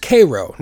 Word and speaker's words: Cairo: There's also Cairo, right? Like in Egypt. In Georgia and Illinois, it's pronounced Cairo.